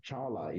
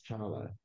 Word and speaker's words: Tchala: My first name is Olowonjo, and my last name is actually Chala. You don't pronounce the T. – Ah. – But it does have that T there, that T-C-H-A-L-A. The first name, last name is Chala.